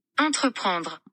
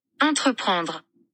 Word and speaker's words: entreprendre: Entreprendre. – Entreprendre. – Entreprendre. – Entreprendre. I don't have it. Okay, but that is the original pronunciation from the French word for entrepreneur,